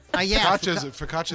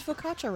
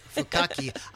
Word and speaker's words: focaccia: They spelled focaccia right on the thing here. – Nice, that's tough. I make it, I can't spell it. – Fukaki. I used to think it was Fukaki.